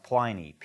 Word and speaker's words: Pliny: I'm not sure if some people say Pliny, but I think it's pronounced Pliny, P-L-I-N-Y.